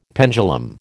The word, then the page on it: pendulum: American English